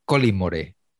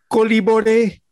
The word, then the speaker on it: Collymore: Pero me hacía mucha gracia cómo le llamaba García, cómo pronunciaba su apellido García, que era Colin Morey. – Stan Collymore la ha vuelto a liar.